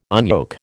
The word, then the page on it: unyoke: American English